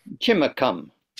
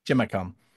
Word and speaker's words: Chimacum: Shinmakam, Washington. I don't know if I pronounced that right. – Chimacum. – Chimacum.